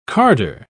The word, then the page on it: carder: British English